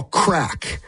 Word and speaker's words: craic: It's more about what they call, and this is the problem, it's more about what they call crack. But it's an old Irish word. It's spelled C-R-A-I-C.